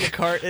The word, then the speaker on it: Descartes: I know who Rene Descartes is. – I mean, he's a heck of an artist. Right? – I know that the S is silent on the end of Descartes.